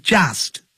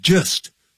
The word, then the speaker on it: just: When I was just 1 year old, 当我只是，这个 just 就等于 only, 不要念成 just, 要念成 just, 肚子饿的饿饿 just, when I was just 1 year old, 我才一岁的时候就搬到台湾来了。I began to learn English.